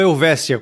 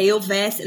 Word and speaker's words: Elvetia: É eu, que eu, ontem quando a gente conversou, você falou Elvésia com, com como se fosse um S. É, é, é assim que se fala, certo? – É, na verdade, sim, o nome da editora é, é Elvetia. Na verdade, se escreve com T, é Elvetia,